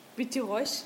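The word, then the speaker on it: Witte Reus: Witte Reusch. – Also das ist W-I-T-T-E für Weiß irgendwie und Riese heißt hier R-E-U-S, Reusch.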